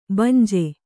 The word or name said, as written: ಬಂಜೆ